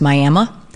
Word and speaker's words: Miami: So when I lived in Missouri, there were two places kind of in the area, spelled Miami, pronounced Miami, and one that's spelled Nevada, pronounced Nevada.